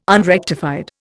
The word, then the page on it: unrectified: Indian